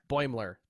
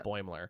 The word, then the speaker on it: Boimler: Boimler. – There it is. – Yeah. – Boimler.